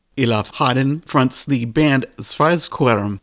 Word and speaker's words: Svarteskerm: Yeah, so it's Svarteskerm. So you almost nailed it, like 99%.